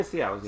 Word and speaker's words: Cialis: Yeah, Cialis.